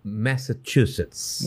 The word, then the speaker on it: Massachusetts: Massachusetts.